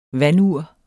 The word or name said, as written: vandur